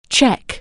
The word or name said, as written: Czech